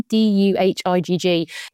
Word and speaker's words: Duhigg: I think you'd say his name, Duhigg.